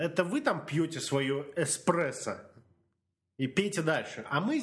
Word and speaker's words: эспрессо: Экспрессо. Экспрессо. Да, такая. Кофе называется эспрессо. Эспрессо. Не надо говорить экспрессо.